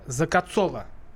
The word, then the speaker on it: Закоцола: У нас сегодня в гостях эксперт по вопросам семьи и брака Валентина закоцала. Правильное ударение? Закоцова. Вот, теперь я исправился, я приношу свои извинения.